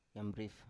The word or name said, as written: yambrif